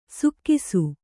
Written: ಸುಕ್ಕಿಸು

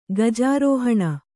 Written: ಗಜಾರೋಹಿಣಿ

